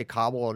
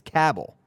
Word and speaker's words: Cabell: He physically intimidated Willie, but his mother says she does not like it when people say Cobble. He says he's heard it all. It's Cabell. Rhymes with Scrabble.